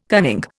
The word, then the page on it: cunning: Indian